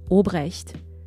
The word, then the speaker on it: Albrecht: You know, in German, we would pronounce it Obrecht.